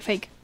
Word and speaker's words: fake: Fake. – ¿De faque? ¿De fake? – De fake.